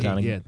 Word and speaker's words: Donaghy: Donaghy, is that how you say his name? Donaghy. Donaghy.